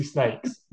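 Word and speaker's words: Snayx: You're like, how would you pronounce this word? Like Yeah. – snakes. – Yeah, obviously snakes.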